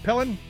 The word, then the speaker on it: Pillen: So, Nebraska, the governor of Nebraska, a guy named Jim Pillen, I think it's how you pronounce it, Pillen, P-I-L-L-E-N, evidently signed an executive order.